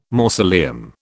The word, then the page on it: mausoleum: British English